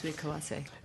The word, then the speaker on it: Hasui Kawase: Is that pronounced correctly? – Yes, Hasui Kawase.